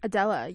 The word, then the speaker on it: Adela: That was Machine Girl by Adela. Is that how you say it? – Adela, yes.